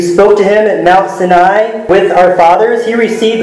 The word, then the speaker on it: Sinai: In Exodus 20, Moses came down from Mount Sinai. My kids say there's another pronunciation for that, but I think that is the one correct pronunciation.